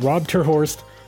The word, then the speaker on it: Rob ter Horst: Well, in Dutch, we would say Rob ter Horst, but oh.